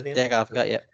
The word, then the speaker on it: Advocaat: Did you, it was actually, must have been the same ground, they were interviewing, is it Dick Advocaat, is that how you say it? – Dick Advocaat, yeah.